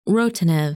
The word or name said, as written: Rotanev